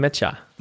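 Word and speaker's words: Nmecha: Ich glaube, ein Matcher ist richtig, Matcher, auf jeden Fall, ja.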